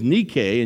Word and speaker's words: Nike: The correct pronunciation, the Greek word is Nike, and you probably hear it more in the translation we hear all the time is Nike, like the Nike shoe.